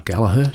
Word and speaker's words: Gallagher: We also heard from Rory Gallagher, or Gallagher, should I say, is the correct pronunciation, from the Against the Grain album from 1976, I think it was, and 75.